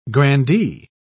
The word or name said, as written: grandee